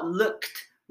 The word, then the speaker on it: looked: はい、 I が主語、 looked。 look の過去形ですね。 ed がついて t 発音です。 looked。